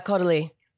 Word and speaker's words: Caudalie: But right now, as far as like brands go, I'm obsessed with Caudalie, I think is how you say it. – Yeah, Caudalie.